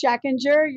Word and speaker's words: Schachinger: Christine Schachinger? That's it? Schachinger? – Yeah, in American, it's Schachinger. You're probably closer to the German pronunciation, yeah.